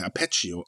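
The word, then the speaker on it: Arpeggio: Apeggio of Blue Steel? – Apegio Sorry, ich weiß echt Steel, nicht, wie man es ausspricht. – Ape äh, Apegio. Ah, so spricht man das.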